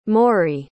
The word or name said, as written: morrie